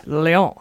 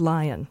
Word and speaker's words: Lyon: Lyon. – I like that. – Actually French. – Yeah. It's not pronounced lion. – Wait, L-E-O-N? – L-Y.